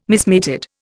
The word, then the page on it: mismated: Indian